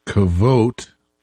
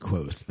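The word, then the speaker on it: Kvothe: So, well, we've been talking about Coat or Quoth as we're pronouncing it. I've also been told he's called Kvote. Hmm. but whichever. – The audiobook pronounced it Quoth, but that's all right.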